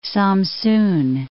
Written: Samsun

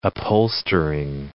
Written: upholstering

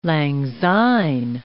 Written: lang syne

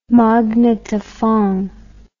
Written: magnetophon

